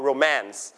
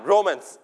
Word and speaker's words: romance: you stress on the second syllable นะครับเราจะไม่ออกเสียงพยางค์และไม่เน้นพยางค์แรกนะครับเน้นพยางค์ที่ 2อ่านว่า romance ไม่อ่าน romance